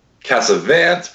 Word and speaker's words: Cassavant: Did I say it right, Mike? – Cassavant, but it's okay. – Cassavant. You're a savant, so yes. It's like a cross between Casanova and a savant.